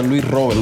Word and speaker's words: Luis Robert: Yeah, in Cuba, siempre se pronuncia Luis Robert.